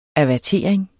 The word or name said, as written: avertering